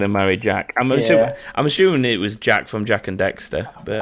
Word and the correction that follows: Daxter: It's not Dexter, is it? It's Daxter, but still.